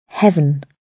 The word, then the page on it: heaven: British English